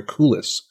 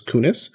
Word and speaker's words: Kunis: I always get her last name wrong. I want to call her Kulis, but that's not her name. It's Kunis.